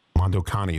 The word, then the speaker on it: Mondo Cane: And if you look up Mondo, C-A-N-E, Mondo Cane, Mondo Cani is the way it's pronounced, you'll see it.